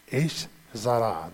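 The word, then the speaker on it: Esh Zara (אש זרה): Esh Zara, that's how it's said in Hebrew.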